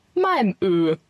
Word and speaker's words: Malmö: Und dann haben wir einen Tagesausflug nach Schweden gemacht, nämlich nach Malmö, Malmö. – wie es wirklich ausgesprochen wird. Wir haben immer natürlich Malmö gesagt, ja. ist so schön eingedeutscht, aber es heißt theoretisch Malmö.